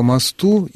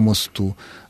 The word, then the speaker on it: мосту: Ирина, вот это как раз тот случай, когда вы можете особенно не сомневаться, поскольку в русском языке есть такие существительные, которые позволяют в формах косвенных падежей ставить ударение двумя способами. Ну вот классический пример, который я постоянно в связи с этим привожу, это слово «мост». По мосту и по мосту, о мосте и о мосте.